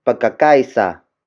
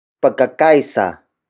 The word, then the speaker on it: Pagkakaisa: This lesson is brought to you by Pagkakaisa. Pagkakaisa. Pagkakaisa. Pagkakaisa is spelled as P-A-G-K-A-K-A-I-S-A.